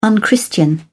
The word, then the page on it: unchristian: British English